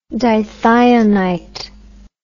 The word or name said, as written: dithionous